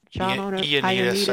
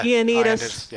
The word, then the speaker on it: Ioannidis: But if, like Ioannidis says, am I saying his name right? – Ioannidis, I think. But yeah. – Ioannidis.